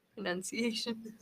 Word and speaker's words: pronunciation: No, it's P-R-O, yeah. – It's P-R-O, but yeah. like, is it pronunciation? – It's not noun. It's none.